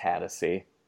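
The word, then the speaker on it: Hatosy: Oh, that stars Sean Hatosi from Alpha Dog. – I believe it's pronounced Hattasi.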